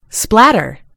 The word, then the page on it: splatter: British English